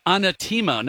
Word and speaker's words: Anatema: Anatema. Now we've got it pronounced right, and I'd heard it pronounced like seven different ways when I was,